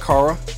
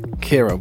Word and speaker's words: Kara: I mean, it's spelled K-A-R-A, isn't that Kara? – Right. There's definitely two ways that many people pronounce it, and so you're just fine. No problem. But it is. I prefer Kara. Kara Nicole. – Kara, but your name is Kiara. – No, it's Kara.